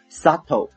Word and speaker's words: subtle: Adults conduct 就是成年人的行为， may exert 也许会 E-X-E-R-T 这个单词呢是动词，产生的意思，也许会产生一种 subtle， subtle， S-U-B-T-L-E，这里面的 B 不发音，它的意思是形容词，微妙的。